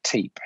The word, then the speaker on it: Teape: It's T-E-A-P-E, Teep.